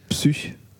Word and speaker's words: Psych: Das heißt Psych. Ich sage jetzt absichtlich Psych.